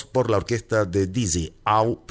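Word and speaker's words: Ow: Vamos a escuchar primero Au, así se escribe O-W y con signo de admiración, Au, ese es el nombre de la composición.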